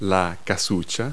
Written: hut

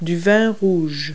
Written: red wine

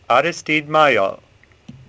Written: Maillol

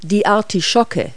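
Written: artichoke

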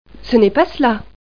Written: non non

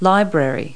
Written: library

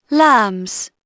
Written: lambs

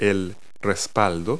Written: headrest

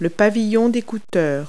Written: earpiece

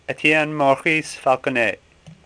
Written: Falconet

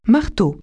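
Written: hammer

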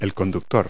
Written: driver

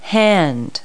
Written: hand

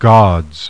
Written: GODS

